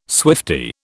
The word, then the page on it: swiftie: British English